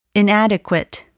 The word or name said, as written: inadequate